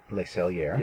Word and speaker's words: Le Cellier: Le Cellier.